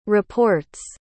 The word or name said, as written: report's